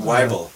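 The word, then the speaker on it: Wyble: Is it Weebly? Weeble? – Weibel. – Weibel. Jimmy Weibel.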